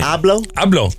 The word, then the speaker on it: Abloh: Abloh.